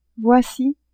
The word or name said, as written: voici